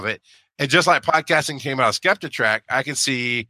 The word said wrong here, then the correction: Skeptic Track: I always say Skeptitrack instead of Skeptic Track.